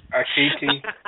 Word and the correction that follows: Akati: Dang it, I messed it up. Sorry, Balaji. I'm sorry, sorry. But anyway, no, it's Akati, the hunter.